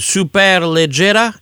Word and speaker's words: Superleggera: Si on parlait maintenant de voitures exotiques, on va commencer par Touring Superleggera. Est-ce que j'ai la bonne prononciation? Bien prononcé.